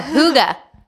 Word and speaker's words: hygge: Oh, hygge. Hygge. But it's spelled H-Y-G-G-E. What, so it's a Danish.